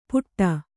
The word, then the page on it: ಪುಟ್ಟ: kannada